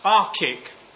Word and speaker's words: ARCIC: ARCIC, which is the most which is the most inoffensive pronunciation of it which I can arrive at.